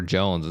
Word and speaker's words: Joens: history, and now we're we're Jones.